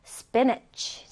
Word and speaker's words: spinach: spinach. 자, 지금 잘 보면 niche요, niche. spinach. spinach.